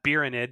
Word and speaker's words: Birinid: That's why I'm Birinid, because it rhymes with Tyranid.